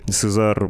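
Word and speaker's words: CAESAR: об уничтожении самоходной артиллерийской установки «Сезар» ни одной, да, иногда это читается как «Цезарь», ну, в общем, французская вот эта техника.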